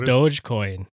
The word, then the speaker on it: Dogecoin: Is it pronounced Dogecoin or a Dogecoin? – Dogecoin. Based on the Doge meme.